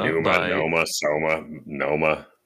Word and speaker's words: Pneuma: Oh, Noma by Tool. – I think it's pronounced. That's like, okay, that's fine. All right. Yeah. – What's it pronounced as there, I think it's Pneuma, right? – Whatever, it's the same shit.